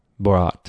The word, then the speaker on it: Borat: Borat.